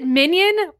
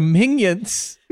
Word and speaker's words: Minion: Do you say onion? – Onion. – Yeah, Minion. – I'm sorry. You're the one who's pronouncing it Minion. You were pronouncing it weird. – Yeah, it's like a little onion.